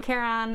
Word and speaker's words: Charon: There's a little debate on how to pronounce his name, but I'm going with that definition. Nope, that pronunciation. Charon helps the dead cross the labyrinthine underworld waters and enter the realm of Hades, the underworld.